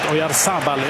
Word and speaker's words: Oyarzabal: Ferran Torres ska komma in istället för 1-0 målskytten i matchen, Mikel Oyarzabal. Förlåt, Oyarzabal. Jag har sagt rätt hela matchen betoningsmässigt. Men jag vill inte säga Oyarzabal, men han heter det.